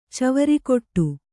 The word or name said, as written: ಚವರಿಕೊಟ್ಟು